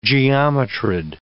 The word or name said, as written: geometrid